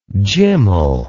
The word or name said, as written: gymel